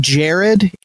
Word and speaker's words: Jahred: And real quick, I'm going to say Jared. It's spelled J-A-H-R-E-D. But I just listened to a podcast like a week ago interviewing him, and the podcaster kept calling him Jared and he never corrected him.